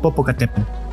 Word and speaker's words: Popocatépetl: Es como Popocatépetl. – Right. Again, the last T is silent.